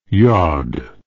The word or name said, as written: yaud